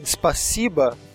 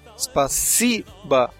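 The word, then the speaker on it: spasiba (спасибо): Como a gente viu na aula passada, spasiba, espero que vocês lembrem ainda, é obrigado, se escreve spasibo. Mas como a sílaba tônica está no meio, em si, spasiba, então esse O do final fica com som de A.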